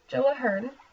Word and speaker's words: Ahern: And I'm so sorry, I have butchered his name all season. I thought it was an M, but it's an RN. So it's Joe Ahern.